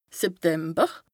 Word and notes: September: Bas-Rhin; Strasbourg

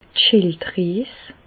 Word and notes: d' Schilddries: Alsatian; Bas-Rhin; Reichshoffen

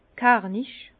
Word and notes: kernich: Bas-Rhin; Herrlisheim

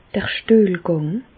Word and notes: d'r Stüehlgàng: Bas Rhin; Reichshoffen

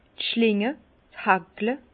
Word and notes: d' Schlinge, d' Haagle: Bas-Rhin; Herrlisheim

